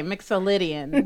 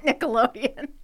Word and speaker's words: Mixolydian: Zion, Mixolydian Cannon. – Mixolydian. – Okay, Mixolydian. – Not Nickelodeon. – I didn't say Nickelodeon. I said Mixolydian.